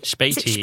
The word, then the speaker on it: Späti: I think also in the book, Berlin Like a Local, you talk about, and it'd be great to hear about, I'm not going to pronounce it right, but Späti? – Späti.